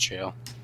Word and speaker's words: Gaol: Jeremiah Brandreth, Gaol, G-A-O-L. – Yeah, that's pronounced jail.